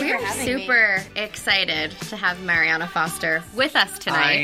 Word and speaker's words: Mariana: Lots of people are saying Mariana, lots of people are saying Mariana, but I pronounce it Mariana Foster, so.